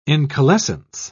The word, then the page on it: incalescence: British English